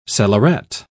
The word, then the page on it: cellaret: British English